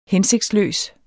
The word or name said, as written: hensigtsløs